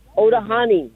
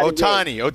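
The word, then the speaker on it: Ohtani: Otahani. I'm horrible at Otani. Shohei Otani. In what inning? – Otani. In the fifth. – Shohei Otani in the fifth. Shohei Otani in the fifth.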